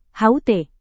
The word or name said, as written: hut